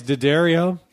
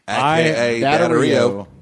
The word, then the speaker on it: D'Addario: Our other sponsor this week is Diderio. – You said it right. – Diderio.